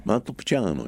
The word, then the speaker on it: Montepulciano: Montepulciano? – Montepulciano, yeah.